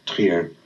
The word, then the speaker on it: Trier: I, I say Trier, but I think they actually pronounce it Trier okay.